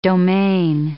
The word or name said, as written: domain